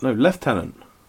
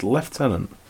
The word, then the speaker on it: lieutenant: You're not, lieutenant. You're British. It's lieutenant. – Lieutenant? – Yeah, German. – Yeah, no. He was downgraded to lieutenant. – Why do we pronounce it like that when it is actually lieutenant? – It's because it's French.